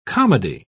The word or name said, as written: comedy